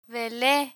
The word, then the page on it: vele: Spanish